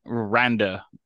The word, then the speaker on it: Randa: It's Randa, really? – Randa, like Randa. R-A-N-D-A.